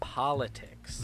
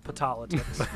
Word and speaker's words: politics: What is the proper pronunciation of that word? – Politics. – Very good. – It is not patolitics. that will find you very unsuccessful speaking to anyone.